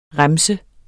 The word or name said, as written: remse